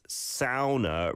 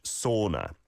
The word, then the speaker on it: sauna: It's originally pronounced sauna, rhyming with cow in Finnish, but is commonly pronounced in English sauna.